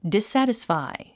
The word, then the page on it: dissatisfy: dissǽtisfài; ディサティスファイ